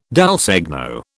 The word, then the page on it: dal segno: British English